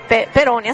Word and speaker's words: Peroni: Perché Marco ha notato prima che c'è questa bella birretta Pironi. – Sì. – Peroni, aspetta, l'ho detto giusto. – Peroni. – Ma che... – Perfetto. – Che vergogna, non l'ho detto giusto. – Ma no, Peroni.